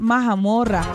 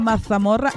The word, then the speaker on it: mazamorra: No es mazamorra, no, es majamorra. Los españoles dirán, esta no pronuncia bien la Z. Es que nosotros hablamos así, ve. Si yo hablo así, la mazamorra, no me van a entender.